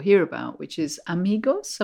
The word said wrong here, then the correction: Imago: Am I saying it right? – Imago, yeah.